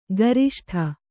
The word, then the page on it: ಕೃಷ್ಟ: křṣṭa